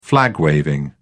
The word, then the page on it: flag waving: British English